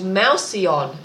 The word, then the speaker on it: mouseion: But there is a word called mouseion, and it's spelled M-O-U-S-E-I-O-N, mouseion.